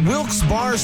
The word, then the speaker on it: Wilkes-Barre: And you're listening to Wilkes Barre Scranton's 97.9X. I had heard that it was supposed to be pronounced Wilkes-Barre. Is that true? Wilkes-Barre Scranton's 97.9X. The only way to pronounce Wilkes-Barre.